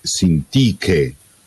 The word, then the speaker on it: Sintiche: Sempre la linea 12 c'è Sintiche, mentre la linea 27 troviamo l'accento sulla prima E, Sintiche.